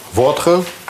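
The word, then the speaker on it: votre: Со словом «ваш» – «вотр», «ваша» – «вотр», а «ваши» – «во», «возами» – «ваши друзья».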